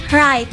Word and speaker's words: write: The word is write. – Write. – Do not say write. – Oh, jadi gimana? – Jadi huruf W-nya itu silent.